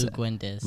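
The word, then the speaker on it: Luguentz: But, like, Dort from ASU – I'll mispronounce his first name. – It's Luguendez.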